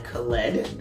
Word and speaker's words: Khaled: DJ Khaled has started the press run for his 12th studio album by not only announcing the project's title, which is Khaled, that is his name and that's how it's pronounced,